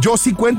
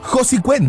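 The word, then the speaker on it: Josi Cuén: cómo se dice, Josi Cuen o Josi Cuen, cómo se pronuncia el nombre de este gran artista. Dice el buen David Josi Cuen porque se escucha más nice, ¿no? Más fresh sound. Pero en realidad sí se debe de pronunciar Josi Cuen, ya que es la, vamos a decir, el conjunto, la abreviatura de los dos nombres de este artista, que se llaman José Isidro Beltrán Cuen.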